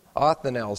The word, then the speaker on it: Othniel: Othniel is how you pronounce that.